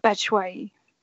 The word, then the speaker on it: Batshuayi: Batshuayi. Am I saying that right? Batshuayi. – Batshuayi.